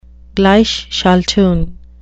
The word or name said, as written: gleichschaltung